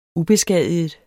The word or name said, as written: ubeskadiget